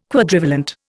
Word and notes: Quadrivalent: Indian

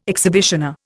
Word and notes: exhibitioner: Indian

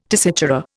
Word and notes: tessitura: Indian

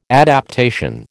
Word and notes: adaptation: American English

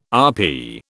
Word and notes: RP: British English

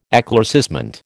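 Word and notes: éclaircissement: American English

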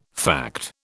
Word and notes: fact: British English

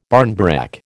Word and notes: barnbrack: American English

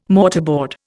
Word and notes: mortarboard: Indian

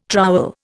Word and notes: trowel: Indian

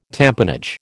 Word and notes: tamponage: American English

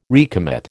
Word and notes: recommit: American English

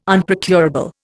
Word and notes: Unprocurable: Indian

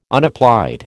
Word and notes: unapplied: American English

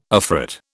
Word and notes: afrit: British English

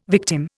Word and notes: victim: Indian

